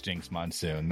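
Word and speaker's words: Jinkx Monsoon: Yeah, it's Jinx Monsoon.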